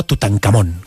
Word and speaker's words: Tutankamón: La Real Academia de la Lengua dice que hay que pronunciarlo y decirlo como Tutankamón.